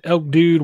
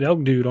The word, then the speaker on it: Elk Dude: You can find me at Elk Dude YT on Twitter and Instagram, or you can find me at Elk Dude on YouTube just the way it's spelled.